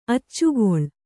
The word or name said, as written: ಅಚ್ಚುಗೊಳ್